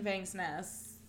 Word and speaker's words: Vangsness: We recently supported a Kickstarter where it's by Kirsten Vangsness. That's how you pronounce it.